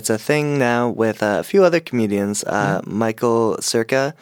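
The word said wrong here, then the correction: Cera: It's Kara. – Oh, right. I thought he was putting the hard C in the middle.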